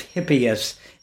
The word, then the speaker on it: Hippias: Moving on a little, what is the quadratrix of Hippias? Excuse me if I've mispronounced one or both of those words. – You've mispronounced neither.